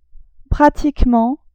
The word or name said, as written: pratiquement